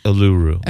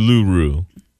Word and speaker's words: Uluru: it's Uluru. – Okay. U- Uluru.